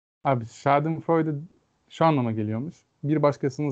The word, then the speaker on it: Schadenfreude: Bir tanesi Schadenfreude Al- Almanca bir kelime.